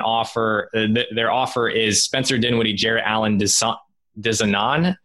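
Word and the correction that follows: Džanan: Am I pronouncing that right? – Jonan. – Okay, Jonan. John and Musa, and a protected first round pick in exchange for Drew Holiday.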